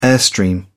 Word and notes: airstream: British English

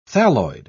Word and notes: thalloid: British English

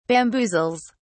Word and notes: bamboozles: British English

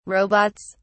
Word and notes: robots: British English

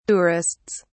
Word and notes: tourists: British English

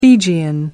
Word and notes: Fijian: American English

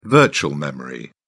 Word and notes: virtual memory: British English

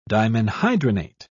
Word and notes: dimenhydrinate: British English

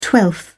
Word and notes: twelfth: British English

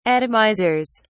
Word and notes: atomizers: British English

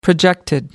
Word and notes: projected: American English

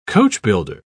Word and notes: coachbuilder: British English

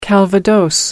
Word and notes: calvados: American English

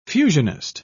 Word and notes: fusionist: British English

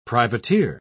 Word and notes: privateer: British English

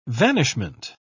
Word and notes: vanishment: British English

